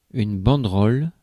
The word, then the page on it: banderole: [bɑ̃d.ʁɔl]; France